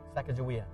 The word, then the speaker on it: Sacagawea: Shakazawiya? Oh, how do you say her name? Shakazawiya. Yeah, she's your guide. Sacagawea. I was talking in tongues there for a second.